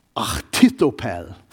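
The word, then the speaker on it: Ahithophel: It's ach. I'm going to use that ach sound. Achitophel.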